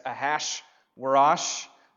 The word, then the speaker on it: Ahasuerus: That's Ahasuerus. You don't have to pronounce it that way. I'm told to. The textual guys say that's how you say it.